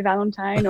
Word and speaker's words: Valentine: Yeah, without the accent is fine too. You can say Valentine or whatever suits you.